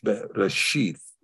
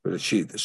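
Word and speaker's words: Reshith (ראשית): So our last letter being the Tav, pronounced in this case, some people say sheet, but in this case, TH, Reshith, Reshith.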